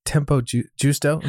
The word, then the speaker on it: Tempo Giusto: You wrote about this, and I'm just curious to ask you, but about Tempo Giusto.